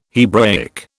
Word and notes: Hebraic: British English

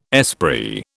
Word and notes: esprit: British English

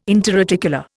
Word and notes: interarticular: Indian

